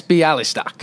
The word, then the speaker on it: Bialystock: B- Max Bialystock.